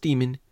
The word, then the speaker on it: Daemon: Now, I know it's pronounced demon,